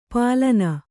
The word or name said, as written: ಪಾಲನ